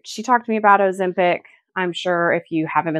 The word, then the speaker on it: Ozempic: Ozempic.